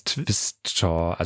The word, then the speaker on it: Tvistter: Twister, also mit geschrieben T-V-I-S-T-T-E-R.